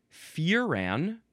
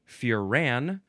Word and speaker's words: furan: The next word is furan, or furan, emphasize either syllable. It is just spelled F-U-R-A-N.